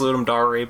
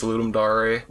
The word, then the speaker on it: Ludum Dare: It's pronounced Ludum Dare, by the way, guys. Ludum Dare. – Ludum Dare.